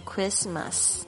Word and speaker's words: Christmas: 其实 Christmas 圣诞节就是纪念这个耶稣诞生的这个时间嘛，那么耶稣基督就是 Jesus Christ，这个 Christ 就是它的拼法就是 C-H-R-I-S-T，但是念成 Christmas 的时候呢你会发现这中间的 T 呢是没有发音的，是直接就念 Christmas，但是在拼的时候不要忘记中间有一个 T。